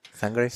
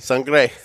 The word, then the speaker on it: Sangre: I think we did the Google pronunciation thing beforehand, and I think it's Sangre. Sangre. – Sangre.